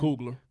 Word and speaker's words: Coogler: What's how you say his name? – Coogler.